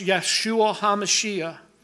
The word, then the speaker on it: Yeshua HaMashiach: Yeshua, Jesus Christ is pronounced Yeshua HaMashiach.